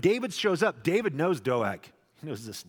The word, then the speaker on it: Doeg: Doeg, as his name is pronounced, but you can just call him Dog, the rest of us do that know him well.